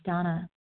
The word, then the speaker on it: Dana: This is Donna B. I'm a recovered compulsive overeater and bulimic calling from Denver, Colorado. I do spell my name D-A-N-A. Although pronounced Donna.